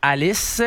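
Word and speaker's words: Halys: Alexander Zverev, le grand favori, qui fait face à Quentin Alice. Je pense que c'est comme ça qu'on prononce son nom. – Oui, exactement, le français.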